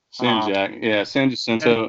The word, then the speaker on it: San Jacinto: San Jacinto.